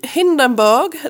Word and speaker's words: Hindenburg: I Hindenburg, det går inte att inte säga det på tyska.